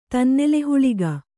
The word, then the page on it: ತನ್ನೆಲೆಹುಳಿಗ: Kannada